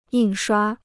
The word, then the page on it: 印刷: yìn shuā